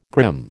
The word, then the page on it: grim: American English